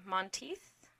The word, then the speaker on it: Monteith: And Corey, how do we say his last name? Monteith? – Yeah, that'll do.